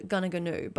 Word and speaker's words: Goonoo Goonoo: This place is about 20 minutes south of Tamworth and it's called Gunagunu, but it's pronounced, or not pronounced, it's spelt Goonoo Goonoo Station.